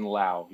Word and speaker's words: Laue: I'm Kevin Atlas now, but I grew up as Kevin Lau. Yeah, L-A-U-E.